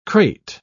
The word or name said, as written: crate